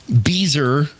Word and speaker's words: Beezer: Very, very talented libertarian artist, Scott Beezer. And it rhymes with geezer.